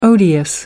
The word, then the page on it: odious: American English